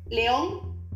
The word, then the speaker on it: León: Entonces, es una obra de arte escuchar a cantar a Leo Larregui. Eh, se llama Leo. ¿Cómo se transcribe? ¿Cómo se escribe? Leo. – León.